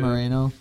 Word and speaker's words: Moreno: How do you pronounce his name? Moreno? Is that how you pronounce his name? – Moreno, yeah. Moreno.